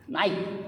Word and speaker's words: Nike: Naik, Nike bacanya kalau bahasa Inggris ya.